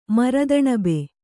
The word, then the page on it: ಮರದಣಬೆ: Kannada